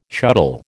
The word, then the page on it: shuttle: American English